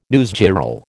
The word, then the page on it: newsgirl: American English